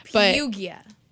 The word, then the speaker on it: Perugia: So in 1911, this guy named Vincenzo Perugia. That's probably very mispronounced. – Perugia.